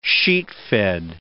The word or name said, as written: sheetfed